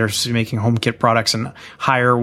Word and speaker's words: Haier: Like the Chinese brand Haier, H-A-I-E-R. Am I pronouncing that one right? – You are.